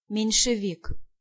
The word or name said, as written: Menshevik